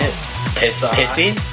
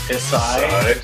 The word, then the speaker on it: Psy: Pissy? – Psy? – Psy? Psy? Is that it? – Psy? – Unless, I mean, I assume it's a silent P.